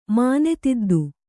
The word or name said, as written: ಮಾನೆತಿದ್ದು